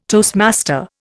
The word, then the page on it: toastmaster: Indian